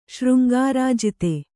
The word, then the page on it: ಶೃಂಗಾರಾಜಿತೆ: Kannada